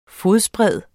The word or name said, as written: fodsbred